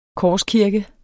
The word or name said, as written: korskirke